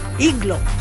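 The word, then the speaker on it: Agloe: Iglo. Se escribe Aeglo.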